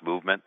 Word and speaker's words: MVMT: It's a MVMT watch. It's pronounced Movement, and it's a great looking watch.